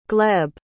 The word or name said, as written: gleb